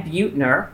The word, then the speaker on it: Buettner: Today we have Dan Buettner, I said it correctly, yeah? – You got it.